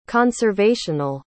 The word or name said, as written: conservational